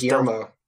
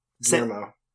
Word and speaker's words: Guillermo: Guillermo del Toro. Have I said that right? Because everyone just says Guillermo. – Yeah, there we go. – Guillermo.